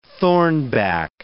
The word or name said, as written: thornback